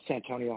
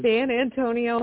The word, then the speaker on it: Santonio: If you were hankering to hear Santonio Holmes' name mispronounced a few times. – San Antonio Holmes.